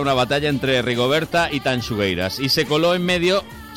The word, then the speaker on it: Tanxugueiras: Sí, las chungueiras. ¿Lo he dicho bien? – Tanxugueiras. – Tanxugueiras. Me ha salido un chunguitos en gallego.